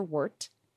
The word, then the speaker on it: wort: Then you boil your malt with water, and that's your wort. Thank you again, Sheldon, for that pronunciation note. Wort. Like wort, wort, wort.